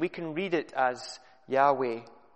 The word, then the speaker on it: Yahweh: Until relatively recently, it was widely regarded that the pronunciation would have been Jehovah. But modern scholars have proved this to be an error, and the pronunciation is more along the lines of Yahweh.